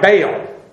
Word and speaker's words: Bel: And this, it's B-E-L, it's spelled that way. But it's pronounced like Baal.